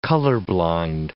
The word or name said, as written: color-blind